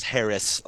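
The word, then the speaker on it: Haracz: No, it's actually just Harris. Like it's spelled goofy oh, Polish. Oh, that's cheating, Mike.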